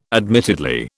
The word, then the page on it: admittedly: British English